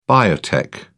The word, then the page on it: biotech: British English